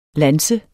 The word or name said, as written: lanse